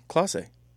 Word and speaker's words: Klose: Are you saying that right? Klose? – Klose. – Okay. – That's how. – How do you spell it? – K-L-O-S-E. I'm pretty sure it's Klose.